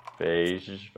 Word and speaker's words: Feige: their their directive from from on high from Kevin Feige.